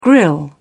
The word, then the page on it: grille: British English